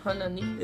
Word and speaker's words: Hanani: E aí, inclusive, vem o sacerdote lá depois, o, o Anani, Hanani, sei lá como é que se pronuncia. – Hanani. – É, Hanani. Hanani.